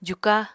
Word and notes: juka: apatani

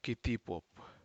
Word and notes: kitipup: Aneityum

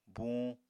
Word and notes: buñ: apatani

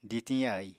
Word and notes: ditiñ yaì: apatani

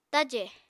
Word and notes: tajè: apatani

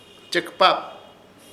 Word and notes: Chepɨkaap: Ishir Chamacoco

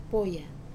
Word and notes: poya: wamesa

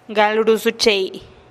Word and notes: gal ruusuchei: TdVZ

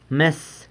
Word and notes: mes: sanlucasquiavini

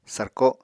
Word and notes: sarko: apatani

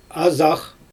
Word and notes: azax: Xyzyl

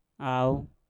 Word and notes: au: mpur

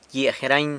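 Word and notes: gyia jerany: San Lucas Quiaviní Zapotec

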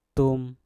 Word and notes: tum: mpur

